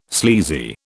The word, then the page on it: sleazy: British English